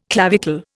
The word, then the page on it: clavicle: Indian